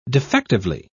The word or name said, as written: defectively